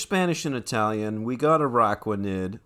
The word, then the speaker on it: Araquanid: Araquanid.